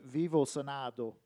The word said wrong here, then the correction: Vivo Sonhando: Vivo Sonhando. – Sonhado.